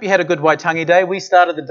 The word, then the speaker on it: Waitangi: It's Waitangi Day, pronounced correctly.